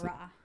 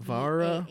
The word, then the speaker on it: Vahraa: How do you pronounce Vara. – It? Vara. – V-A-H-R-A-A.